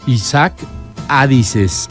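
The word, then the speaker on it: Ichak Adizes: Te voy a invitar a que busques su video de PAEI y se escribe con Y, así como si fuera Isaac, pero con Z, Y-Z-A-K, Jisak Adises, así.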